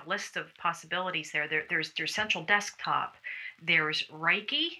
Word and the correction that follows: Wrike: I don't know if I'm pronouncing that right. It's Wrike.